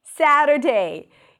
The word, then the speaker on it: Saturday: Saturday. You start with the syllable sa, that's the primary stress. Then we have a flap T and a schwa. Sadder, end it with an R. Sadder, and then day.